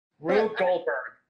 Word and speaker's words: Rube Goldberg: So now I'm stuck inside a fucking Ringberg machine? I can't pronounce it right now. – Rube Goldberg. – Yeah, thank you.